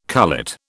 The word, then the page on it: cullet: British English